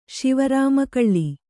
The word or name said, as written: ಶಿವರಾಮಕಳ್ಳಿ